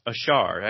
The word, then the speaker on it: Achard: Okay, so just so everybody knows, it's spelled Achard, but the D is silent. Is that correct? – Correct. You don't pronounce the D. Yeah. – Okay, so Alex Achard.